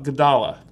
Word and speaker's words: amygdala: It's the amygdala. – Amygdala.